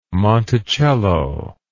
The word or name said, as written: Monticello